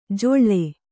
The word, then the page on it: ಜೊಳ್ಳೆ: joḷḷe